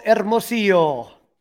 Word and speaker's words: Hermosillo: Michael Hermosillo. – Very good. Almost as good as Pat Hughes rolling that H.